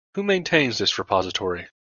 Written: maintains

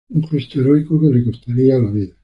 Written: gesto

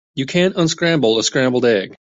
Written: unscramble

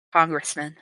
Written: Congressman

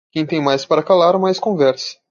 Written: calar

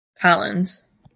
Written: collins

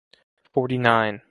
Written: forty-nine

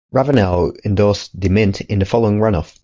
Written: endorsed

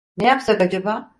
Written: acaba

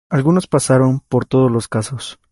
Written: pasaron

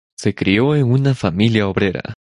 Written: obrera

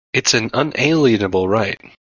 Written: unalienable

